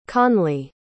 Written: connley

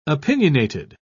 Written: opinionated

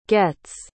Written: gets

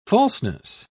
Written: falseness

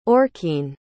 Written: aurkene

